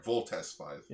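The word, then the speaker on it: Voltes V: We're talking about Volt 5. – Is that how that's pronounced? – Yeah, it's 5. – It's spelled V-O-L-T-E-S space V. – Yeah, sorry, Voltes 5.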